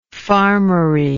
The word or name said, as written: farmery